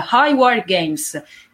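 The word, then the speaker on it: Highwire Games: Cioè, rappresentare un contesto del genere è molto complesso, ma considerando che non è quello l'obiettivo degli sviluppatori, che ho pronunciato malissimo perché mi ricordavo male il nome, quindi ripronuncio adesso correttamente, High War Games,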